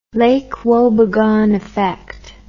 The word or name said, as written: lake wobegon effect